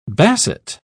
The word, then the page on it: basset: British English